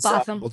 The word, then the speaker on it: Botham: What did Botham Jean's, I don't know if I'm pronouncing his name, Botham Jean's? – Botham.